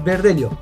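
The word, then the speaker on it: Verdelho: Creo que se pronuncia verdeló por aquí. No, es verdelio.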